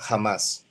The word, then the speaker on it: Hamas: La cosa es que su acrónimo en árabe el acrónimo, la reducción, se pronuncia jamás, por eso le decimos jamás.